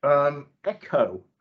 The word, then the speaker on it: Eckoh: In terms of other kind of tech, Echo PLC, ticker's ECK, that's spelled ECKOH, Echo PLC.